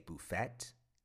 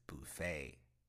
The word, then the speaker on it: buffet: Buffet. I enjoyed the buffet at the hotel. Don't say buffet. The word is buffet.